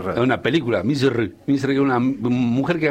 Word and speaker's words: Misr: Pero es eso, como te digo, hay muchos lugares que han sido, por ejemplo, Egipto, en la lengua original, ¿cómo se dice? Misr. Y nosotros nada que ver, decimos Egipto. – ¿Cómo se dice, perdón? – Misr.